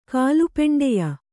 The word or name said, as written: ಕಾಲಪೆಂಡೆಯ